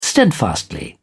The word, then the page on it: steadfastly: British English